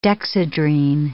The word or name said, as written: Dexedrine